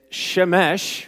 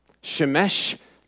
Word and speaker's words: Shemesh: It's Shemesh, which is the sun part, okay, and of righteousness, which is tzedakah. Shemesh tzedakah.